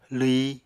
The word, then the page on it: lwyi: apatani